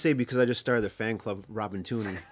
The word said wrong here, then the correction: Tunney: I've been Tunney, by the way. – Tunney. – You know how to pronounce the name right.